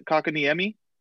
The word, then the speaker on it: Kotkaniemi: Kotkaniemi? – Yeah.